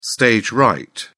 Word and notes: stage right: British English

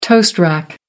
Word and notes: toast rack: American English

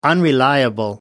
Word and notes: unreliable: American English

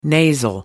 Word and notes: nasal: American English

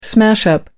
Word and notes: smash up: American English

